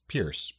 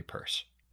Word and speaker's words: Peirce: whose name looks like it should be pronounced Pierce, but it's actually Peirce.